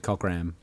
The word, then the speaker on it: Cockram: It's better if you say it Cockram. – I'm Cockram. – Yeah, that's how it's spelled.